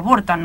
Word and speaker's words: Burton: ¿Por qué te pusiste Capitán Barton? O Burton, no sé cómo lo pronuncias vos. – Barton, sí, porque Barton.